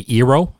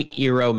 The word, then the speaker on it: Eero: the Eero, and that's spelled E-E-R-O, it's fantastic.